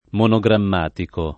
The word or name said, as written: monogrammatico